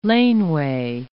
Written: laneway

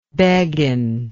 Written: beguin

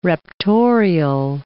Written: raptorial

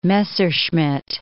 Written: Messerschmitt

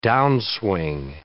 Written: downswing

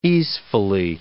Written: easefully

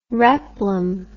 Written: replum

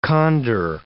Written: condor

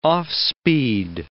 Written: off-speed

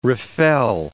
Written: refel

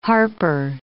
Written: Harper

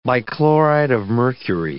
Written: bichloride of mercury

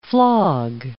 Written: flog

